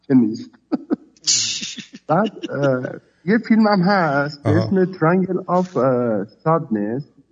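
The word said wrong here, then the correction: Triangle of Sadness: اگه اشتباه تلفظ نکرده باشم، مثلث غم، تراینگل آف سادنس.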